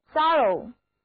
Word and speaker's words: thorough: 那表示完全的、彻底的，在英语中还有一个单词叫做 thorough， thorough， T-H-O-R-O-U-G-H，字母 O 读作 a，O-U 读作 a，而 G-H 是不发音的。